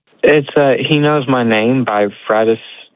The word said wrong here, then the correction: Francesca Battistelli: I'm not sure how you pronounce her name at all. – Oh, Francesca Battistelli?